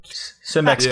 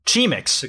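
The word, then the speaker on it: Cimex: Simex kinesis. Chimex kinesis. Whatever the fucking bug trends like to Chemix. Chemix kinesis. What the fuck? Did you pronounce that like Chemix? That's a C. C-I-M-E-X. Chemix?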